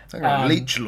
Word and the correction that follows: liege: lord, I've not heard that word before. – You know, like liege. – Liege. Yeah, liege.